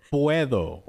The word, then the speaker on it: puedo: Puedo.